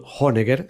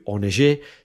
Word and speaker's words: Honegger: Honegger, si lo pronunciamos a la alemana, o Arthur Honegger, si lo hacemos a la francesa.